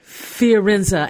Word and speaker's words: Fiorenza: Is it Forenza? How do you pronounce her last name? Elizabeth? – Oh, Elizabeth Schuessler Fiorenza. – Fiorenza.